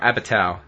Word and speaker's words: Apatow: Apatow.